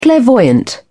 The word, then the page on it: clairvoyant: British English